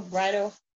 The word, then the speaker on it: bridle: Do not be like a horse or a mule without understanding, whose temper must be curbed with bit and brittle. a bit in a brittle is something that they put in their mouth and then bridle. Did I say it right? Brittle, bridle, B-R-I-D-L-E.